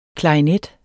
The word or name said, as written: klarinet